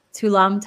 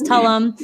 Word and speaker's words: Tulum: Radames Rubio Gayton while visiting Tulum, but now they are dating. And that's Tulum, right? I got that right? Tulum. Yes. Don't, yeah. See, I was thinking of like tulips. It's Tulum.